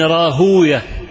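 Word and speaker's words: راهويه: النحاة يقولون وي، أما المحدثون يقولون أوية. إسحاق ابن راهوية، المحدثون هكذا ايه ينطقونها، أما أهل اللغة فيقولون إسحاق ابن راهوي.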